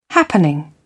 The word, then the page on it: happening: British English